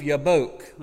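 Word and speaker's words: Jabbok: Verses 22 through 24, you see, Jacob sends his family and possessions across the stream of Yabok, and that's the way you actually pronounce that in Hebrew.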